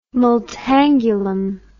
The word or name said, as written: multangulum